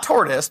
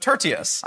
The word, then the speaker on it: Tertius: Not Tortoise, but Tertius. I think that's how you pronounce it.